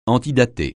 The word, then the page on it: antidater: francais